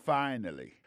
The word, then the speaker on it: finally: Finally, c'est F-I-N-A-L-L-Y, finally. T'as des prononciations, oui? Finally. Accent tombé sur le premier syllabe, là. Finally. F-I-N-A-L-L-Y, ça veut dire en bon créole, enfin, séraphin, pied fin, manger à réussir, arriver sous table, là.